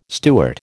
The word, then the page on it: Stuart: American English